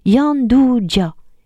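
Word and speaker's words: Gianduja: Gyan Duja, prononcé Yan Duja.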